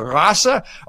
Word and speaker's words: Rasse: Okay, the word race was originally printed in German text spelled R A C E, and no German understood how to pronounce it because there's no such uh, uh, con- combination of letters. So what they had to do was change it to Rasse, R A S S E, and then you could produce it.